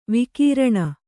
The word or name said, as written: ವಿಕಿರಣ